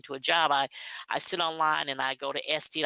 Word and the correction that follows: Etsy: I know I'm saying it wrong, because every time I say it, Buddha says, "You're saying it wrong. It's ETSY. Is that Etsy or SD?